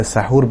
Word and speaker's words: সাহুর: সাহরি মধ্যরাতের পরে করলে কোনো অসুবিধা নেই, তবে সাহরির ক্ষেত্রে, যেটাকে আরবিতে সাহুর বলা হয় মূলত, সাহার অথবা সাহুর, বাংলায় যদি আমরা সেহরি বলি, শব্দটার আরবি উচ্চারণ হচ্ছে সাহুর, এই সাহুর যদি কেউ মধ্যরাতের পরে তিনি নেন অর্থাৎ পানাহার করেন তাহলে কোনো অসুবিধা নেই, যদি তিনি দেরি করেন সেটাই হচ্ছে সুন্নাহ।